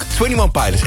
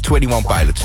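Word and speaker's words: Twenty One Pilots: Take Me Back hoorde je daarvoor ook 21 Pilots. En dat spreek je dus wel gewoon uit als 21 Pilots.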